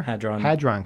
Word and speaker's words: Hadron: Okay, we basically have all heard about the large, how do you say it, Hadron Collider? – Hadron Collider, yeah.